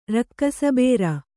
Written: ರಕ್ಕಸಬೇರ

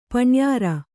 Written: ಪಣ್ಯಾರ

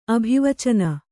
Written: ಅಭಿವಚನ

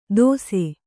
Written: ದೋಸೆ

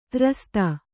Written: ದುರಾಸದ

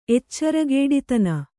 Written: ಎಚ್ಚರಗೇಡಿತನ

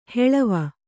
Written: ಹೊಳವು

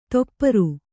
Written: ತೊಪ್ಪರು

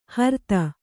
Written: ಹರ್ತ

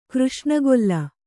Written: ಕೃಷ್ಣಗೊಲ್ಲ

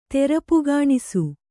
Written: ತೆರಪುಗಾಣಿಸು

